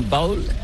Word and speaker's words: bowl: C'est prononcé comme bowl. Mais c'est bowl. – Bowl, mais c'est pas comme un bol. – C'est B-O-W-L.